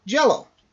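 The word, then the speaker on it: Jellÿ: So, Green Jello, which is spelled J-E-L-L-Y with umlauts over it, is pronounced, according to the band, as Green Jello, is an American comedy rock band formed in 1981.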